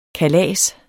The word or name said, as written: kalas